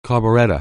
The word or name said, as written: carburetor